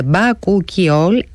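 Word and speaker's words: Bakuchiol: Η δερμοκαλλυντική σειρά Μπακουκιόλ προφέρεται Μπακουκιόλ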